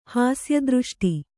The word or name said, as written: ಹಾಸ್ಯದೃಷ್ಟಿ